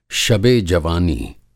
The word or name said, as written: शबेजवानी